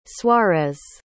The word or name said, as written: suarez